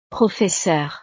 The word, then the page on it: professeure: frances